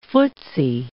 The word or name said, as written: footsy